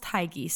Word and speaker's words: Hygge: Now, if you've ever walked by a bookstore or seen this on Amazon or whatever, it is pronounced Hygge. So maybe you're like, what the fuck is Hygge? It's Hygge, but that's how they pronounce it in the Danish world.